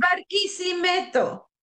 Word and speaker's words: Barquisimeto: Barquisimeto.